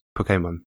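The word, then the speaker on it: Pokémon: The accent on the E would make the emphasis on the E. So it would be Pokemon.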